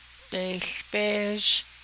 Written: deiłbéézh

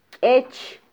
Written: kyech

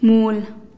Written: muul

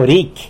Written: ricy

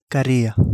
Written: galia

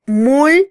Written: mul

